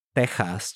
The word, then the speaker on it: Texas: First of all, Texas in Spanish is pronounced Texas, just the same as in how Mexico in Spanish is pronounced Mexico, right?